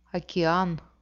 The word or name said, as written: okean